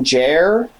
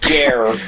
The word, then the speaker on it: Jarre: J-A-R-R-E, I'm not sure how to pronounce that. – We're not going to start pronouncing names directly on our 30th episode. – I think I heard it pronounced Jarre, Kevin Jarre.